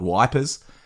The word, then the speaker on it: Ypres: And obviously, they weren't too concerned with the French pronunciation because, you know, famously the town of Ypres up in Belgium, they called Wipers.